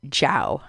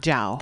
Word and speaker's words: Zhao: And you pronounce her name Zhao. – Zhao. – That's Zhao. – How you say her last name.